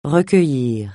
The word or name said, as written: recueillir